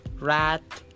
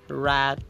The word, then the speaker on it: read: Hanya saja cara baca verb 2 verb 3-nya beda. Jadinya read, read, reading.